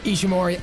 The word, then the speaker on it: Ishimori: Ishimori, I think it's going to be him that is going to retain the championship.